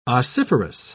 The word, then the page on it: ossiferous: British English